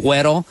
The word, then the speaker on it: Güero: Yeah, his album, Güero, he forgot the umlaut on top of it, the two dots on top of the U, so it's a mispronunciation.